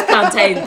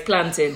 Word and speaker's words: plantain: No, you're not one of those plantain people. No, it's planting.